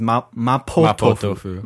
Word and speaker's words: mapo tofu: I think they use the Chinese pronunciation, which is mapo tofu.